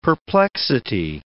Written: perplexity

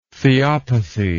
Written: theopathy

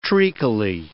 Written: treacly

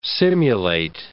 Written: simulate

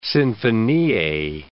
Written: sinfonie

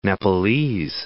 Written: Nepalese

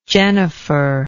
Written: jennifer

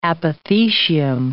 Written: apothecium

